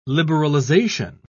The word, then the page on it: liberalisation: British English